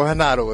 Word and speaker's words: Renato: We had a guy named Renato Tavares, or Renato if you want to use the Portuguese pronunciation.